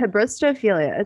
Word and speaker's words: Hybristophilia: Hybristophilia. It's spelled Hybristo and then philia.